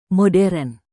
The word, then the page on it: modern: Indonesian